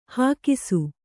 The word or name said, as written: ಹಾಕಿಸು